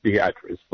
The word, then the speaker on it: Beatrice: spelled Beatrice in the rest of the world, but pronounced Beatrice locally.